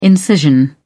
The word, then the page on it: incision: British English